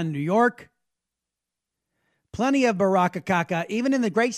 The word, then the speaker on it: Baraka: A guy named Raz Baraka rhymes with caca.